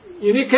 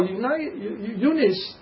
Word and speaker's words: Eunice: That's a Unike or Eunice, as you all pronounce the word.